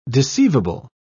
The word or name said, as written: deceivable